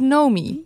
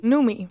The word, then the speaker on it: Noemi: Of is het Nomi? – Noemi. – Noemi, ja. Want ik heb hiernaar geluisterd, maar ik heb het dus afgelopen weken de hele tijd als Nomi uitgesproken. Maar het is Noemi.